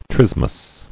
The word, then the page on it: Trismus: tris·mus